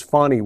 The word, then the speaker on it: Fani: I mispronounced her name in previous accounts. It was not intentional. I was not trying to make a joke of her name. I just was wrong. It is Fonnie Willis, F-A-N-I.